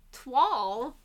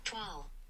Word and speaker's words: toile: Twal. – Twal.